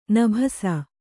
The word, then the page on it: ನಭಸ: nabhasa